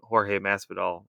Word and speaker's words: Jorge Masvidal: Not so much the guy who got fucked by a decision, but like you really wanted Jorge Masvidal or whatever. Did I say his name right? – Yeah.